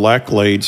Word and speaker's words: Laclede: We got another one called Laclede's, how you pronounce it, L A C L E D E, uh, Laclede Gas.